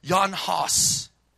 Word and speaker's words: Jan Hus: Or if you're to pronounce it right, Jan Hus.